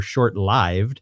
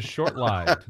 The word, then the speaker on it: short-lived: Hank, at Flatlander Hank, says, "At Stormfront Freak, by the way, freaks, don't panic on the pronunciation of short-lived or short-lived. According to current dictionary entries, it can now be pronounced either way."